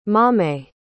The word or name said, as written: maame